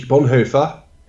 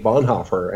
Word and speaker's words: Bonhoeffer: Of course, then it was Dietrich Bonhoeffer because I'm from the Netherlands. And it was only later that I realized that there were a lot of people who pronounced his name Bonhoeffer and that there was a very lively community in the United States that studied his work.